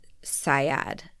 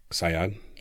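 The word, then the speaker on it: Saad: Leila Sayad. – Sayad? – It's S-A-A-D.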